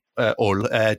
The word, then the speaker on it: Hull: Hull. It's how you say it, don't you? Is it Hull? – Yeah, we can't pronounce the letter H. So you've just pronounced it. Never mind.